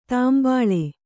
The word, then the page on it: ತಂಬಳಿ: tambaḷi